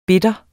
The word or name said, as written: bitter